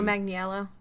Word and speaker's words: Manganiello: Joe Manganiello.